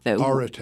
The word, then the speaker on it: Arete: Arete. Yeah, yes, yeah. That's how you pronounce it.